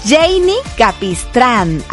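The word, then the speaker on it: Jeinny Capistrán: Y se escribe así mi nombre, J-E-I latina, la del puntito, ajá, doble N, una N, otra N, ajá, Y, Capistrán, Janie Capistrán.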